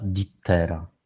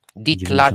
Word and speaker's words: Dietla: Ditla, nie Dittera. – Ditla. Nie jesteś z Krakowa, więc nie znasz tego. – No dokładnie, Ditla. – dokładnie. – Ditla. Pisze się Dietla. Dietla. A czyta się Ditla.